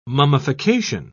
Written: mummification

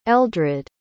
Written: eldrid

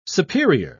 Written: superior

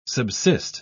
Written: subsist

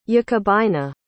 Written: jacobina

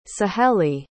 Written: saheli

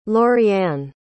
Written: loriann

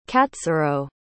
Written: katsurou

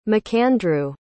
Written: mcandrew

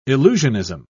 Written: illusionism

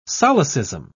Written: solecism

